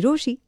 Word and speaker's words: Rochie: मेरा नाम है मिर्ची रोशी,